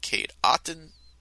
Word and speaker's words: Cade Otton: Cade Otton, I think that's how you say it. Yep, Cade Otton had a touchdown, and so did Trey Palmer.